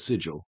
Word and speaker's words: Sigil: I love it being, I've had a group who went to the city of Sigil, or as I call it, Sigil, because that's the correct way to pronounce the word.